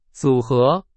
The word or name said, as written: 组合